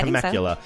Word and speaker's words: Temecula: So we got Nicole Oswill of Temecula, California. I'm saying that right? It's a fun word to say, and I think I've heard it before. – Yeah, Temecula.